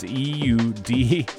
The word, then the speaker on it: Eudaimonia: Is it Eudaimonia? It's Eudaimonia. But either way, it's forthcoming on Celsius, and it's a great tune. Pronounced Eudaimonia, and it's out November 10th on Celsius Recordings.